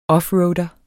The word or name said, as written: OFFROADER